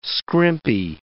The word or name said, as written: scrimpy